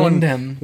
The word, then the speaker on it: Wyndham: Is it W-Y-N-D-A-M? – H-A-M. – H-A-M. Oh, you throw Wyndham. In Y's as vowels and H silent H's?